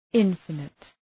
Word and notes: infinite: {‘ınfənıt}

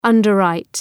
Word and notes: underwrite: ,ʌndər’raıt

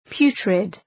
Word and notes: putrid: ‘pju:trıd

